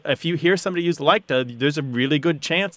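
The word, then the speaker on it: liketa: So this is called counterfactual likta.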